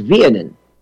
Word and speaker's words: Weenen: The assignment helped me with the pronunciation here of something that's spelled W-E-E-N-E-N. So it looks to us as Weenen. W-E-E-N-E-N. He helped me. The pronunciation, as I remember it, is Vienen. – That's correct. That's perfectly correct.